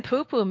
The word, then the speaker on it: pupu: And it's spelled P-U-P-U, everyone. – P-U, poo-poo. Maybe it's not even poo-poo. Maybe it's spelled a little or pronounced a little differently. Have you thought about that? – No, it's totally pronounced poo-poo.